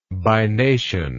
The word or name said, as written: bination